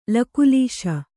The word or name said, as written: ಲಕುಲೀಶ